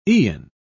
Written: eon

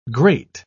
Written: grate

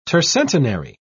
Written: tercentenary

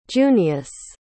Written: junious

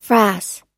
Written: frass

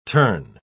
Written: turn

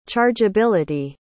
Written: chargeability

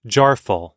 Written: jarful

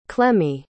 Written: clemmie

